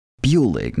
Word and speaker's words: Buhlig: And today we have Joe Buhlig. Now, I am pronouncing that right? – You nailed it. Just like Bueller, but IG instead of ER.